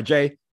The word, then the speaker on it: Jai: It is Jai Lucas. If he man wants to go by Jai, we'll call him Jai. It's funny that he goes by Jai because he is the son of John Lucas II, who had a 14-year career in the NBA.